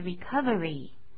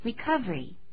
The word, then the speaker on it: recovery: 有两种念法，比较慢比较仔细的念法是 recovery，可是讲话快一点的话我们会念成 recovery 这样就好了。